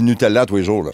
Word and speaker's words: Nutella: Comme ma responsabilité de parent, ce serait de lui faire comprendre que la bonne prononciation, c'est Nutella.